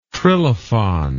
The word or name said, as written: trilafon